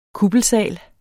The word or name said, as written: kuppelsal